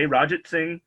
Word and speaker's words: Ranjitsingh: But Ranjitsingh is, one, not hard to pronounce once you hear it, and two, it's three syllables.